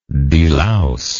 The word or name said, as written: delouser